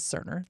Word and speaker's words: Cerner: And Dolores writes in and says, the pronunciation for Cerner is Cerner. That company that I didn't know if it started with a hard C or a soft is an S.